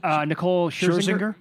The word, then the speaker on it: Nicole Scherzinger: Nicole Scherzinger.